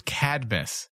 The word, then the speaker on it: Cadmus: Dave, it's Cadmus. – Yeah, you always throw in an additional syllable, and it throws me off.